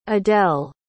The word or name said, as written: adell